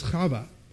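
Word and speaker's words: Chava (חוה): That's how we pronounce Eve. In Hebrew, it's Chava.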